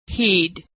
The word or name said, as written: he'd